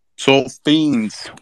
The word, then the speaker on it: SolFiends: SolFiends. – SolFiends.